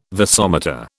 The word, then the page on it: vasomotor: British English